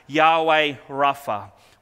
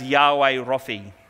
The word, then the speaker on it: Yahweh Rapha: And today we are going to be looking at Yahweh Rapha, which means the Lord who heals. This is sometimes pronounced as Yahweh Raphi.